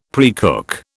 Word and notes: precook: British English

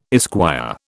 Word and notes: esquire: British English

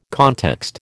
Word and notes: context: American English

